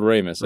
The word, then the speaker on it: Ramis: Is it Raimi? – Harold Ramis, I think it's pronounced.